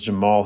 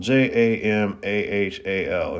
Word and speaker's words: Jamahal: But uh the main event turned into being Johnny Walker versus Jamal Hill. Uh, they they keep saying it's Jamal in the broadcasts, but it's spelled Jamahal, J A M A H A L.